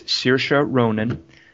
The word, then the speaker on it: Saoirse Ronan: Oh, so finally, I'm like, okay, we got to know how to say her name, and we've all practiced, I presume. It's Saoirse Ronan.